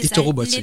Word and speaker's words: éthorobotique: Est-ce que je le prononce bien premièrement? – Oui, c'est l'éthorobotique. L'éthorobotique, donc comme le nom l'indique, finalement c'est l'utilisation de robots pour étudier le comportement des animaux.